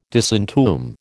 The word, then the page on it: disentomb: American English